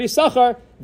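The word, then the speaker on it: Yissachar: The first time we pronounce the word Yisachar, then you read both sins.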